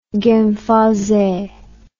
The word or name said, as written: genfersee